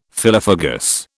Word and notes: phyllophagous: British English

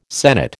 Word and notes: senate: American English

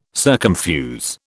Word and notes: circumfuse: British English

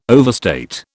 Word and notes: overstate: British English

